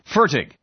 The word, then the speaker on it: Fertig: Fertig. – Thank you. I lived in Germany. It's a German name, and in German it would be Fertig, but Fertig. Okay.